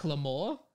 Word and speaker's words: Lamore: Which is spelled Jacques Lamore. Kind of sounds like Macklemore, but he wasn't around in 2007.